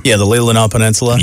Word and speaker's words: Leelanau: On Grand Traverse Bay, Traverse City makes for a great home base for exploring wineries on the old Mission and Leelanau peninsulas. Did I get that right? – Leelanau.